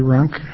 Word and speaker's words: Runk: Madam Chair and members of the committee, my name is Larry Runk. It's spelled R-U-N-K.